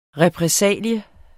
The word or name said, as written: repressalie